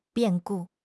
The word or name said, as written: 变故